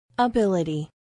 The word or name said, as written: ability